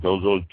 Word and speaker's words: Gozo: Gozo, G-O-Z-O, so it makes sense to be Gozo. And Comino, C-O-M-I-N-O.